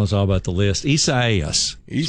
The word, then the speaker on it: Isaias: Isaias. – Well, Isaias. That's how you pronounce Isaias. The name of the system.